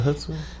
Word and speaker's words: chutzpah: Well, it's like a silent C, right? It's a chutzpah.